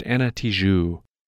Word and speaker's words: Ana Tijoux: A few weeks ago, on an episode that aired back in January, I mispronounced the name of that artist. Knowing that she lives in Chile, I pronounce the J in her name as if it were Spanish, forgetting that she's actually of French descent, so her name should be pronounced Ana Tijoux.